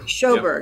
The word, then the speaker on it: Shoberg: Welcome to this episode of Strategies for Turbulent Times with today's guest, Kyle Shoberg. Am I saying that correctly? – Shoberg. – Yep, there we go.